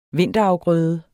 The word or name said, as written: vinterafgrøde